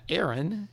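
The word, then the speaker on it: Aron: That's Alicia and Chip, TJ Young, Bridget Clavey, Brandy McBride, Maja, Aaron, pronounced it's A-R-O-N, but it's pronounced E-R-I-N. I was getting it wrong.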